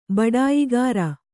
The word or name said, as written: ಬಡಾಯಿಗಾರ